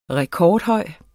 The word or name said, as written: rekordhøj